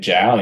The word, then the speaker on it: Jiao: You can search my name, Dan Jiao, and Jiao is spelled J-I-A-O.